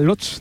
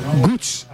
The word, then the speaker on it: Łódź: ¿Sabes cómo se pronuncia Lotz? Te lo voy a decir, es que es muy difícil, Borja. Gutsch.